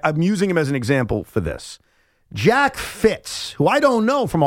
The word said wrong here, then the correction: Fritz: Well, you certainly don't because you keep saying his name wrong. It is Jack Fritz. – Fritz, okay. Fritz. I'm sorry. – F-R-I-T-Z.